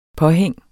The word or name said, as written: påhæng